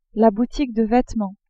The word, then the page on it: boutique de vetements: female